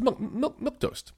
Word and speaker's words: milquetoast: It's milquetoast. Otherwise, it's milk and toast. – Milk toast. – And that's different. It's milk toast.